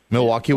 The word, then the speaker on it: Milwaukee: My intention, I had a daughter that left last week for Milwaukee, Wisconsin. Am I saying that right? – Milwaukee, Wisconsin.